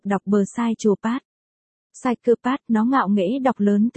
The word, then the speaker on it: psychopath: Psychopath, anh nó cố gắng đọc thật rõ cho nó nghe, vậy còn chữ. P, psychopath, ở đầu vứt đi đâu, nó chu mỏ bướng bỉnh chữ.